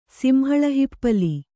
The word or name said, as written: ಸಿಂಹಳಹಿಪ್ಪಲಿ